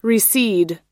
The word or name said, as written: reseed